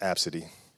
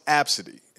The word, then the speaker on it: Abcde: And then when I saw it, I was like, "Oh, her name's pronounced Absidy." And that's what it was. Her name was pronounced Absidy, a little child, but the name was spelled A-B-C-D-E.